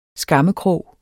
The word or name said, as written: skammekrog